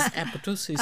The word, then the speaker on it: apoptosis: Which is the P is, I understand the P is silent. So it is apoptosis.